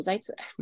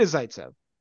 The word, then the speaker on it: Zaitsev: I lean more towards Vlasic staying up and Phillips going down, and then Zaitsev, or how you say his name, Zaitsev. Nikita Zaitsev.